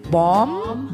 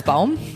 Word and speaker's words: Baum: Heute steht bei uns: Der Name für Oz im Buch Der Zauberer von Oz entstand als sein Schöpfer, und den muss man jetzt, glaube ich, Englisch aussprechen, because he's American. – Yes, he is. – Frank Baum. Frank Baum, geschrieben als Baum.